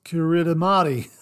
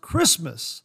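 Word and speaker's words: Kiritimati: Uh, when you look at it, you're thinking it's pronounced Kiritamati, but it's pronounced Christmas.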